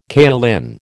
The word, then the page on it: kaolin: American English